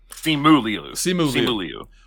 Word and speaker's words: Simu Liu: And Sima, is it Sima Liu? Is that how you pronounce it? I always mispronounce his name too. – Yes. Simu Liu.